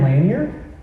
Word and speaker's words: Lanier: There's a book I read recently by a guy named Jaron Lanier. Lanier, I think is actually how you pronounce his name.